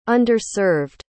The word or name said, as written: underserved